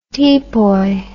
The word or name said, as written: tepoy